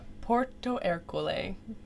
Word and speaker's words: Porto Ercole: Porto Ercole.